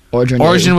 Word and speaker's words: Orgeron: Orgeron, is that how you say it? – Orgeron was apparently outraged, immediately quit.